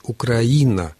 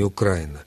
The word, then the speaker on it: Украина: Ну и третья рекомендация, которую дает Людмила Алексеевна Вербицкая, ударение в названии республики должно стоять на предпоследнем слоге, то есть «Украина», а не «Украина», на мой взгляд, совершенно правомерно.